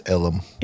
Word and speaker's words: Ellum: How do you say Elm, but you say it in such a jacked up way? It's Ellum.